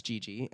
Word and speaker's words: Geggie: Yeah, my name happened because my legal last name is spelled with two G's in it, G-E-G-G-I-E. – Uh-huh. – That's Gigi.